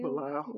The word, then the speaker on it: Belial: Belial.